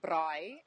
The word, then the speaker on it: braai: We, like I said, we have these big cookouts that we call braai, if you say it correctly in South Africa.